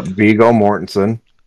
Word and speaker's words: Viggo Mortensen: The Aragorn, Viggo Mortensen, I think is how you pronounce his name, Yep, Viggo Mortensen.